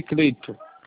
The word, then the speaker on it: Clayton: Escreve Kratos, mas lê Cleito.